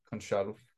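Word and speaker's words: Gonçalves: And when I look at sporting, yes, they have Pedro Goncal- Gonsalves, Gon- Gonsalves. Uh, I struggle with my Portuguese pronunciation, so it's actually probably Pedro Conchal. Conchalve, because they don't do the s. You know, they don't pronounce that.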